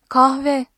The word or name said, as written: kahve